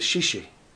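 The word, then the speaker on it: shishi (谢谢): He said, shishi. Is that how you say it? – Yeah.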